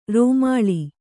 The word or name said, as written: ರೂಮಾಲೆ